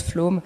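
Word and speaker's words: Flåm: Flams ça se prononce Flome.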